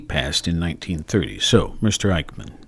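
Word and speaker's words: Eijkman: Christian Eichmann, E-I-J-K-M-A-N. I've seen this in writing, but I truly can't remember how to pronounce it. Anyway, Christian Eichmann. – Eichmann, thank you.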